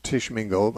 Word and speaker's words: Tishomingo: Never heard of Tishomingo.